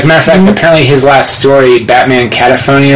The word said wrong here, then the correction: cacophony: or whatever, mm-hmm. Probably not saying that right. – Cacophony. – That actually sounds better.